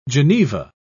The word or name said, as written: geneva